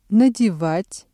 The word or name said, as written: надевать